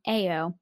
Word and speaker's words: Ayo: And excuse me, you pronounce her name saying Ayo. Like, Ayo.